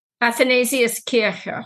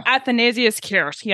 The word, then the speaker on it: Athanasius Kircher: I think I'm saying his name right, as Athanasius Kircher. – Athanasius Kircher.